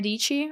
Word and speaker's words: Dici: And as always, my name is Sarah Dici, rhymes with peachy.